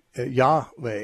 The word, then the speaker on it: Yahweh: Because Jehovah is not anywhere near as likely to be the correct pronunciation, since the J sound doesn't exist in Hebrew. And therefore, it would be Yahweh is usually considered to be more likely the pronunciation.